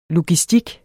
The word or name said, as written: logistik